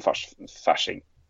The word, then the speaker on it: Fasching: Uh, farsing er vel, eller facing da, er vel det vi har så gjennom små diskusjoner bestemtes for at sånn skal ut, det uttales F-A-S-C-H-I-N-G. Hudson Fashing. Ja.